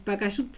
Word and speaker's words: Pacayut: Es Pacayut, se pronuncia así, pero no se tilda, no se escribe con tilde.